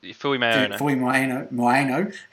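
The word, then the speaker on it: Fuimaono: We get your boy Fuimoano, is that how you say his name? Tyrell Fuimoano, whatever his name is. – Yeah, Fui Moana.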